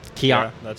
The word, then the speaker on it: Kia ora: So this one is called, I don't even know how to pronounce this, Kiaora? – Kiaora. Kiaora. – Kiaora. – K-I-A-O-R-A.